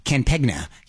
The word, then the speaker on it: campaign: You don't say the G. Campegna.